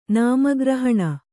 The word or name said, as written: ನಾಮಗ್ರಹಣ